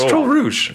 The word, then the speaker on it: Troll Rouge: Troll Rogue. – Troll Rogue. I think it's Troll Rouge.